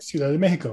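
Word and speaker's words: Ciudad de México: The Mexico City Capitanes, the Capitanes de Ciudad Mexico. Did I say that right? – Ciudad de Mexico, very good.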